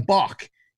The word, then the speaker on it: Bach: So, usually every week, I'm doing it with Dan Bach, Dan_BACK. Looks like back, sounds like Bach, pronounced like Bach.